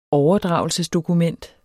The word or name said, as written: overdragelsesdokument